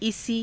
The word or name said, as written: isii